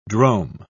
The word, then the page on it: drome: British English